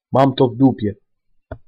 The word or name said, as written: mam to w dupie